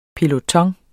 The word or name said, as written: PELOTONEN